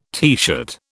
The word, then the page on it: teeshirt: British English